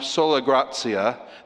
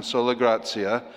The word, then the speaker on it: sola gratia: So, number one then, we'll spend the longest on sola gratia, that is how you say it in Latin, sola gratia, by grace alone.